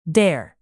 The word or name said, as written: dare